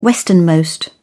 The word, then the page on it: westernmost: British English